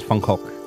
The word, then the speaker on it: Van Gogh: Can I say Van Gogh, by the way? Not Van Gogh. Is that okay? – The actual pronunciation is Van Gogh.